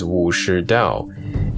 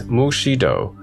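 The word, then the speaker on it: Wu Shi Dao (武士道): The modern Chinese pronunciation is Wu Shi Dao. In Cantonese, Mu Shi Do,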